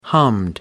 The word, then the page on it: hummed: British English